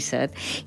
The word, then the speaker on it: set: La majoria pronunciam la E neutra, o sigui, E, per referir-nos a la necessitat de beure, tenir set.